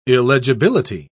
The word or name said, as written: illegibility